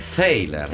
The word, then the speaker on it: Thaler: C'è stato un grande dibattito sulla pronuncia di questo cognome, ma l'Accademia di Svezia dice Thaler. – Thaler, quindi Thaler. Esatto, quindi per noi è Thaler. – Faremo dello spelling, facciamo degli esercizi, perché noi dicevamo Thaler,